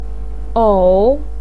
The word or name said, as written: ou3